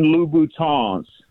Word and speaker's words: Louboutin: And then most importantly, So the women that listen to the show don't start tagging you on YouTube, they are Christian Louboutins. You got to pronounce it with the French.